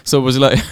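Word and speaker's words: Szoboszlai: Soboslaj.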